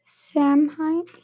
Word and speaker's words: Samhain: I'm going to find out how to pronounce this. Celtic. No, get that word I got. Samhain. – Samhain here.